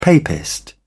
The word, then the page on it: papist: British English